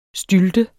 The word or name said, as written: stylte